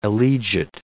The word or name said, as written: elegit